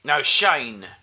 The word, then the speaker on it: Shane: Shane, not Shame.